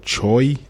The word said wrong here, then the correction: Che: It's Che, actually, but Che. I pronounced it Choi for like the first year and a half until I got this soul and somebody laughed me out of a building.